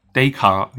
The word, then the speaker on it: Descartes: we can start with the most classic example, you know, the most famous example, which is from Descartes. Descartes. Descartes, yeah. This is the way the English normally pronounce it.